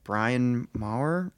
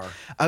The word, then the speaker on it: Bryn Mawr: like Vera Jane Palmer and born in Brian Maher, is that how you pronounce it? – Bryn Mawr.